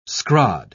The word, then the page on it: scrod: British English